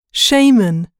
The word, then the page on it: shaman: [ʃɑ́ːmən]